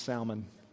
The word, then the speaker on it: Salmon: And I did check this. It is not pronounced salmon. It is pronounced Salmon. So all of you out there who are laughing, saying, he said Salmon and it's Salmon. It's not. It's Salmon.